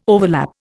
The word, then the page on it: overlap: Indian